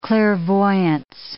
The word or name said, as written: clairvoyance